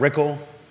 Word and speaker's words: Rickle: Ann Malik and Brad Rickle, rhymes with pickle, are running in the Whitehall District of the Albemarle County Board of Supervisors race.